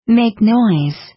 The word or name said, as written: make noise